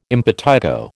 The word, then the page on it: impetigo: American English